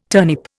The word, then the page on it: turnip: Indian